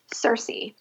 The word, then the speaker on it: Circe: Circe. – Spell that for us. – C-I-R-C-E.